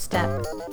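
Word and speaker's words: steppe: It's just step.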